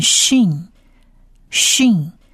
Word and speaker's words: shin: 如果像山的这个字，它的黑点点是在右上方的话，我们把这个字念 shin shin，而他在发音上念许的音，这样子不知道听众朋友会不会弄错。